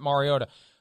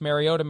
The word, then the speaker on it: Mariota: And thank you for pronouncing it Mariota. I don't want to name names, but my God, when he was playing last Thursday night, it's Mariota, Mariota, Mariota.